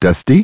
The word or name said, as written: Dusty